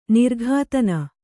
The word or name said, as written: ನಿರ್ಘಾತನ